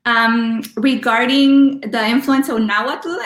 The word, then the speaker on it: Nahuatl: Uh, Nahuatl.